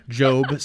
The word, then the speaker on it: Gob: Job, spelled capital G period, capital O period, capital B period, George Oscar Bluth, Job.